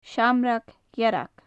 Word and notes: seamrag dhearg: gaelic

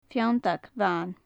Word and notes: feanntag bhan: gaelic

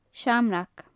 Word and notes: seamrag: gaelic

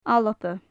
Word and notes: alba: gaelic

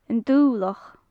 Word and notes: an dublachd: gaelic

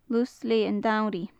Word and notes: lus leth an t-samhraidh: gaelic

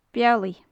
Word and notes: bealaidh: gaelic

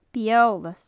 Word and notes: bith-eolais: Gaelic

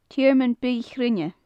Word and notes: tearmann bith-chruinne: gaelic

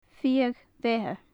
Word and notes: fiadh-bheatha: gaelic

